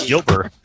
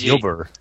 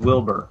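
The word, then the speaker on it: Ylber: It's Yilber. His name is Yilber.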